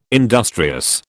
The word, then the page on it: industrious: British English